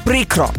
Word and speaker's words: прикро: Наголос завжди на першому складі «прикро». Бо якщо скажеш «прикро», вийде вже не українське слово, а щось нове, новомодне.